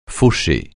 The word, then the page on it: fauché: francais